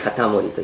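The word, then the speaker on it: 容保: はい、松平春嶽、松平義長で京都守護職、これ会津の松平片森、ね、これ読み方ね、片森と言いますけどね。はい、でこの京都守護職の下にいたのがあの有名な何ですか、新選組ですよね。新選組です。会津中将様お預かり、新選組副長土方歳三、ね、はい、この会津中将様っていうのがこれ松平片森で、ございますね。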